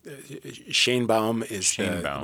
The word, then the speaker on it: Sheinbaum: Are you going to cover as well Claudia Sheinbaum? Is that how you say her name? – That's how we say it in the U.S. Sheinbaum would be the Mexican pronunciation.